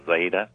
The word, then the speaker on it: Ejeda: And there's a Lutheran hospital presence there called the Zeda Hospital, E-J-E-D-A. It's pronounced Zeda.